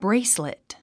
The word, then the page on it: bracelet: bréislit; ブレィスレット